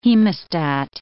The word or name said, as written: hemostat